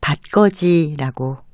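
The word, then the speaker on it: 받걷이: 첫 번째 음절에 받은 바 밑에 디귿 받침을 쓰고요. 그 뒤에는 거 밑에도 디귿 받침을 쓰는데 구개음화 현상에 따라서 받거지라고 발음합니다.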